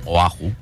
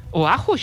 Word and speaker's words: Oahu: Oahu. – Oahu, així és.